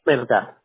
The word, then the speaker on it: Melgar: Dice Robin Vázquez Melgar. – Melgar. – Melgar, correcto.